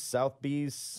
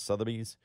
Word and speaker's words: Sotheby's: And they write up, they have the write up, is it Sotheby's, Southby's? – Sotheby's, yeah. – Right? It's pronounced Sotheby's.